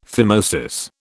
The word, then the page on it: phimosis: British English